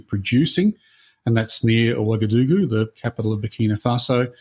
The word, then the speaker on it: Ouagadougou: Ouagadougou.